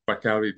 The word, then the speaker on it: Bacary: Bacary Diatta.